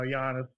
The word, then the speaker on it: Giannis: Last year, it was Team Giannis. I'm not even sure if I'm saying that right. 184. – Giannis. – Team LeBron. There you go, Giannis.